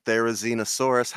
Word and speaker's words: Therizinosaurus: Carson, you're in the winner circle with Therizinosaurus. How am I pronouncing that? Is it correct? – I think we're all nailing it.